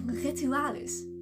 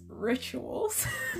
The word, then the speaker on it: Rituals: No, but I was like, Rituales. Rituales. Wat een leuke naam. Wow, what a nice name. Yeah, Rituales. Yeah, like, little did I know that it's actually pronounced rituals.